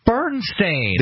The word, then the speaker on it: Berenstain: B-E-R-E-N-S-T-A-I-N, Berenstein. – Right, but that's not Beren, it's Bernstein. – It's not Bern, it's Beren, Beren.